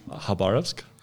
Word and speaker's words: Khabarovsk: Khabarovsk.